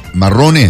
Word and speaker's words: marrone: Marrone. – Testa di moro, que se llama. – ¿Cómo se dice en italiano? Morone. – Marrone. – Marrone, sí. ¿No? – Que es de color marrón o de café.